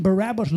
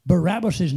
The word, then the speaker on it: Barabbas: Did you know Barabbas' name, we pronounce it wrong in the, in the English. It is Bar Abba, is the way you're supposed to pronounce it in Hebrew.